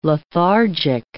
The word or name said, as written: lethargic